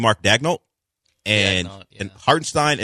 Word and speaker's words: Hartenstein: Because if you missed it, Isaiah Hartenstein, which I finally figured out is a proper way to say it